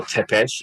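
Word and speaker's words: Țepeș: Vlad Tepes, or Tepes is actually the more correct way to say it, the son of Vlad Dracul, is born.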